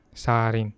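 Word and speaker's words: sarin (살인): Sarin, sarin. It's actually sire and then in, but it's pronounced as sarin.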